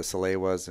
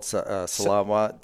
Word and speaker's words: Salewa: And I used to always call it Salawa, too. – I call them Salaways. – Yeah, and Salewa. I was pronouncing it wrong. And actually, we had gotten a guide when we went up into the Dolomite Mountains. And he was a big Salewa fan, and he corrected me.